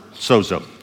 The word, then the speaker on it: sozo: Uh, we would spell it S-O-Z-O in English, but it has a, a, a D in there sounding in Greek, soda, zo, sozo.